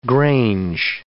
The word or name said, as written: grange